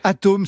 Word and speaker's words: ATOEM: Atome, ça s'écrit avec un E, A-T-O-E-M.